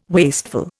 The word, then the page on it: wasteful: Indian